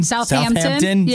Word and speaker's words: Southampton: Am I saying that right? Southampton. – Southampton.